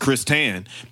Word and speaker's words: Christian: Like my name on paper looks like Christian, but it's literally pronounced Chris Tan.